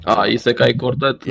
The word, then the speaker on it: Isekai Quartet: Have you guys watched Isekai Quartet? Is that how you say it? – Isekai Quartet. – Yes.